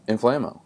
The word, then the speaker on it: Inflamo: So, what is Inflamo, and how did you come up with the idea for it? – Inflamo, and good job pronouncing Inflamo. Most people don't get that right.